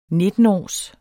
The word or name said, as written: nittenårs